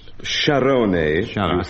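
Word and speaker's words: Sciarrone: Where do you find Scarpia, Spoletta and Scarone? And what are their jobs? – Scarpia was the chief of police in Rome some considerable time ago. Sharone, forgive me for grating your pronunciation. – That is correct.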